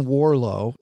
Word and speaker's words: Warrillow: There is a resource there that I like, a guy named John Warlow. That's how you pronounce his name. If you're spelling it, it looks like Warillo. WARRILLOW.